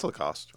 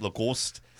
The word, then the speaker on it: Lacoste: I'm fine with it being an actual tiger because, like, you know, horses for polo, you know, alligators for Lacoste or however the hell you say that, Lacoste or – That's Lacoste.